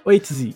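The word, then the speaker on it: Ötzi: Oitzi? Não consigo falar. – Eu acho que é Oitzi. – Oitzi, isso aí mesmo. A primeira pronúncia estava certa.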